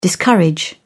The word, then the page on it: discourage: British English